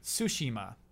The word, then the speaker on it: Tsushima: Tsushima. – Huh. – Equal emphasis. – Oh, yeah, there's no stressing Sushi. Of syllables in Japanese.